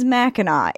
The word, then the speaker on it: Mackinac: And then also Mackinac is Mackinac. It is spelled Mackinac because a lot of the French came to Michigan, this area, first.